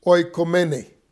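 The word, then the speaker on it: oikoumene: The word for dwelling, to dwell, is the word, I'll spell it for you, O-I-K-O-U-M-E-N-E. O-I-K-O-U-M-E-N-E, which is oikomene.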